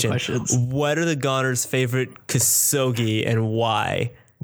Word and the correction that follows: kusoge: That's not a good pronunciation. – Give me that way. – What is the Goners' favorite kusoge game? – That sounds very similar to what I said.